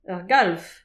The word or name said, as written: ur galv